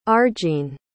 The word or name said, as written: arjean